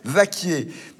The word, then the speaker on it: Vacquiers: Au fait, Vaquier, ça s'écrit V-A-C-Q-U-I-E-R-S. Vaquier.